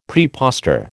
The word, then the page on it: praepostor: American English